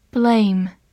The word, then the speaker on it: blame: Blame, b l a m e, blame，字母 a 发它本身的音 a，末尾的 e 不发音。